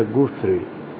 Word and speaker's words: Guthrie: Se pronuncia Guthrie.